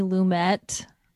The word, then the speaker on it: Lumet: So the director is Sidney Lumet. I thought it was Lumet, but I looked it up and it's, you do pronounce the T, but now it's, I can't Lumiere.